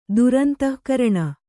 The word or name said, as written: ದುರಂತಃಕರಣ